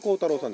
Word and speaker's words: 光太郎: はい、では 3月13日、 1883年、明治 16 年 3 月 13 日の生まれになったのが高村光太郎さんです。日本の詩人であり彫刻家と、本名は光太郎と書いて光太郎と読むとね。